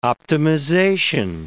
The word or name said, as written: optimization